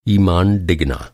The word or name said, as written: ईमान